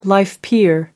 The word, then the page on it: life peer: American English